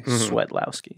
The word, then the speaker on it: Swiatlowski: So the IA becomes like a soft E. So it's actually just like sweat, like you're perspiring. Swiotlowski.